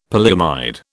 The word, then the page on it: polyamide: British English